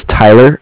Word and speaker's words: Tyler: So my name is Tyler. It's T-Y-L-E-R. And I'm calling with Cardiff. It's spelled C-A-R-D-I-F-F, as in Frank Frank.